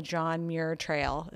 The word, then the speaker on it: John Muir Trail: So please tell us a little bit about your love for the mountains and how you ended up on the John Muir Trail.